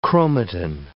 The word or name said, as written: chromatin